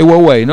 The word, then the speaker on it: Huawei: Es Huawei, ¿no? Porque no, la H no se pronuncia porque no es norteamericano, ¿no? Digamos, ¿no? No es inglés la marca, ¿no?